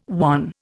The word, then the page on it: wan: Indian